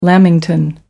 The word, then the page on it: lamington: American English